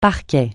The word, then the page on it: parquet: francais